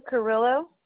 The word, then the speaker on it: Carrillo: Anna Carrillo.